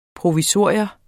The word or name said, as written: provisorium